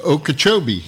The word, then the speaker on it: Okeechobee: But we're going up to Lake, just west of Lake Okeechobee.